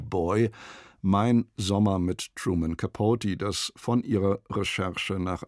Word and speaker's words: Capote: Well, before I answer that question, I think I should explain that my name is really pronounced Capote. Everyone in Munich. Everyone calls me Capote. It's really Truman Capote. Capote.